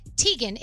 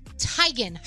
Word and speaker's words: Teigen: Chrissy Teigen says we've been saying her name wrong all along. It's not Teigen, it's Teigen.